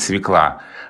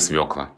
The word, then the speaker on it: свекла: Именно с таким ударением. Есть, знаешь, распространенное мнение, что давно уже правильно говорить «свекла». Вот нет, по-прежнему правильно свекла.